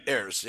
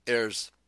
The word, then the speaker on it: heirs: Heirs, yeah. H is silent. – Heirs.